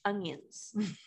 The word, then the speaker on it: onion: There's no G in onion.